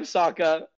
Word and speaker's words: Sokka: That's right, I'm Sokka. It's pronounced with an Aka, young ladies.